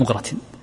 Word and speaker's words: مغرة: المغرة هذا هو الذي يستخرج منه الناس، طبعا بعضهم ينطقها بفتح الميم وبعضهم ينطقها بضم الميم مغرة، وبعضهم يصوب المغرة لأن أصل اللفظة أعجمية وهي في اللغة الأعجمية بالضم، فتكون أقرب لنطق الأعاجم بالضم.